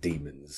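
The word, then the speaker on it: Dæmons: And as I told you just before we came here, I actually looked it up and Barry Letts pronounces it demons. So obviously that was his intention.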